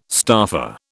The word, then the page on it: staffer: British English